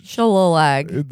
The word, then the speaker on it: shillelagh: The Shillelagh. – The Shillelagh. – That's how it's spelled. – It is a wild spelling. – Yeah. – For shillelagh.